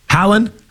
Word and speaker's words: Haaland: And then, am I saying it right? Haaland? – Yeah.